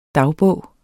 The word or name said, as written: dagbog